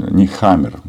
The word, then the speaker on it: Нехаммер: И в данном случае Карл Негам... Нехаммер, наверное, правильно таким образом произносить его фамилию, он, э, он же был в Киеве буквально на днях.